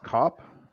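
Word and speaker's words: Kape: So, yes, Manel Cape round one. That is for me. – Nice. – I think it's pronounced Cop. But yes, Manel Cop. That is correct.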